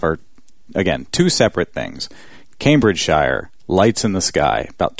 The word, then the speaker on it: Cambridgeshire: Although we've been informed, that it's pronounced Cambridgeshire.